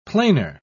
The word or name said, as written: planer